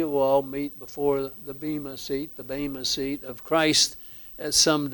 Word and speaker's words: bema: So the word behind seat there is bema. Or bema, some pronounce it.